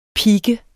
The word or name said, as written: pike